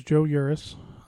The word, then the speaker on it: Joe Yuris: My name's Joe Yuris.